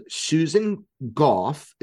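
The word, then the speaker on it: Gough: And I happen to know, and you asked him on stage earlier, that he was the one that told Susan Goff, is how you say her name, Susan Goff, to be honest with the American public.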